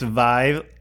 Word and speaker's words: Vive: Apparently, it's Vive. It rhymes with five.